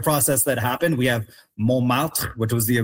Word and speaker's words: Montmartre: So Montmartre.